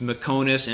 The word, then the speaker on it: Meconis: It's Mekonis, M-E-C-O-N-I-S, that's Lithuanian.